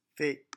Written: fée

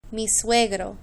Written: mi suegro

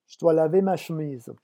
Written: je dois laver

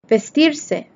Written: vestirse

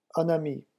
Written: un ami

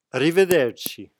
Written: arrivederci

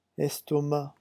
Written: estomac

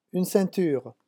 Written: une ceinture

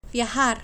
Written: viajar

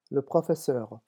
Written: le professeur

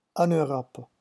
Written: en Europe

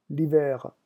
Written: l'hiver

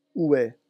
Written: ouest

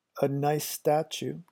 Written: a nice statue

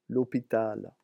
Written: l'hopital

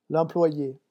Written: l'employe

